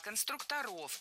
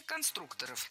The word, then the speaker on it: конструкторов: Конструктора, конструкторов, это неверно. В слове «конструктор» ударение должно сохраняться на втором слоге, на «у». Поэтому конструкторы, конструкторов. Кстати, если речь идет не о профессии, а о «Лего», например, то ударение все равно падает на второй слог.